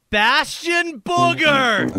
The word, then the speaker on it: Bastion Booger: Bastion Booger! I think is how you pronounce his name. Did I pronounce it correctly? – You got it. – Okay. – You got it, yeah.